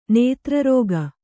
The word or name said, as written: ನೇತ್ರರೋಗ